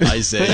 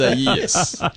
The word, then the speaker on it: Isaias: Like they're like, it's not Iglesias, it's not Isaias, it's Isaias.